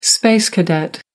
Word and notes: space cadet: American English

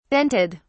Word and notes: dented: British English

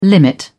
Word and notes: limit: British English

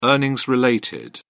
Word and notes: earnings related: British English

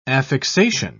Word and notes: affixation: British English

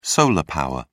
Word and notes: solar power: British English

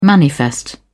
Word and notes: manifest: British English

